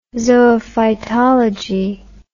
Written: zoophytology

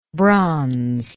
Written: bronzelike

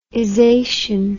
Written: ization

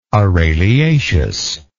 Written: araliaceous

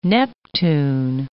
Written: Neptune

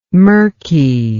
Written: mirky

